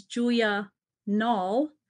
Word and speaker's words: Julia: So my name is Julia Nall, but the the first name is actually pronounced Julie, because my parents named me Julia and they decided to call me Julie.